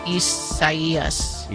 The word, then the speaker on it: Isaias: Well, no, but it's probably the Spanish rendering of it, which if that's the case, then the I is pronounced like a E. – Isaias.